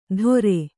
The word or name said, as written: ತೊರೆ